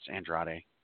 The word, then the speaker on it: Andrade: On the kickoff show, we have Andrade going one on one with Rey Mysterio. We'll let you go first, but can you properly pronounce Andrade? – Andrade. – Yeah, right. Perfect.